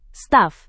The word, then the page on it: staf: Indonesian